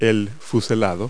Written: fairing

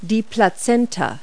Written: placenta

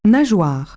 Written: flip

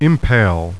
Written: impale

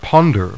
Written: ponder

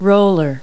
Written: roller

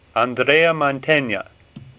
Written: Mantegna

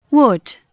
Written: wood

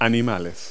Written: animals

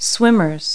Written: swimmers

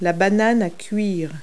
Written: plantain

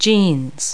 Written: jeans

